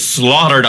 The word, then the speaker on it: slaughter: It, laughter and slaughter are—they're basically spelled the same, slafter. But pronounced differently. Yeah. Slaughter.